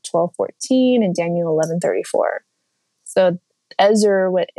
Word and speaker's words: Ezer: So the Hebrew word, and I might butcher it, it's Ezer, E-Z-E-R, Ezer, and it means to, in this context, depending on how it's pronounced, to rescue or save, or to be strong.